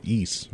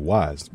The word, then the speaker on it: Ys: It's pronounced something like East or something like that. But, YS. I've often called them both. – I called it Wise the first time I ever saw it.